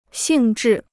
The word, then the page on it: 性质: xìng zhì